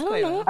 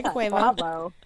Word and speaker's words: Quavo: Yeah, it's Quavo. – Oh, okay, Quavo.